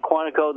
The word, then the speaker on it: Quantico: Am I pronouncing it right? Quantico, the FBI. – Correct. – There's a TV show called Quantico.